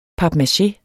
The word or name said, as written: Papmache